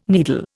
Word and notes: needle: Indian

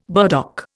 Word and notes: burdock: Indian

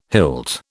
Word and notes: hilt: British English

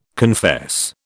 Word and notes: confess: British English

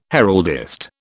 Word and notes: heraldist: British English

